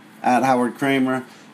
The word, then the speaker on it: Howard Kremer: Please follow me on Twitter, @HowardKremer, on Facebook at Howard Kremer, and my name is spelled HOWARDKREMER.